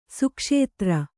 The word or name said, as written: ಸುಕ್ಷೇತ್ರ